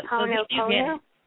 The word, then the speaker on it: Ho'oponopono: Okay, but you said it's Ho'oponopono? – It's spelled H-O apostrophe. – Okay. – O-N-O-P-O-N-O.